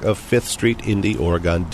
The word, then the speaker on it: Oregon: Unlike the state of Oregon, the end of which is pronounced like begin, the proper noun here, used by Daytonians, is pronounced Oregon, with an ending that rhymes with John.